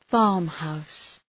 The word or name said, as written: farmhouse